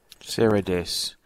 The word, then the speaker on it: Seradess: S-E-R-A-D-E-S-S. – Sarah Dess.